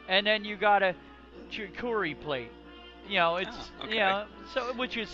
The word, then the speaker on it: charcuterie: So, I mean, mixed olives, fruits and cheeses, and the it's called the charcuterie. – Cutlery plate, like, I can never pronounce that word. But you're right, it's meats and cheeses on like a wooden platter, I believe. – Absolutely, it's charcuterie plate.